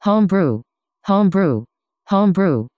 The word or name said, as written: homebrew